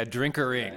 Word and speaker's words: drinkering: Yeah, drinkering. – Yeah, it has to rhyme with tinkering. – Okay. – I didn't come up with drinking rhymes with tinkering.